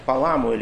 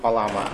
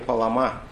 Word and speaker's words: Палама: И в сегодняшний день Святая Церковь вспоминает святителя Григория Паламу, или Паламу, в зависимости ударения на церковнославянском, на второй слог Палама, а на греческом языке Палама.